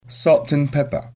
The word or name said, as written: salt and pepper